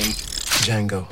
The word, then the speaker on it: Django: Django. D-J-A-N-G-O. – Django Unchained. – The D is silent.